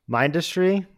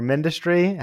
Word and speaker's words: Mindustry: low graphic quality game, and it's called Mindustry or Mindustry, however you pronounce it. It's industry with an M in the front,